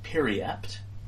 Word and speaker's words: periapt: It's apparently pronounced periapt, and it is a small amulet on a thin chain.